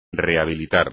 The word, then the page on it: rehabilitar: espagnol